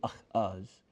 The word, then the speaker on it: Ahaz: That's how you say it in Hebrew, Ahaz.